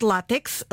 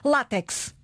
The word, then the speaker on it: látex: Sem mais demoras, a pronúncia correta, ainda que a corrente seja outra, látex. – Com acento no A. – Com acento no A, na escrita acento no A, pronúncia látex.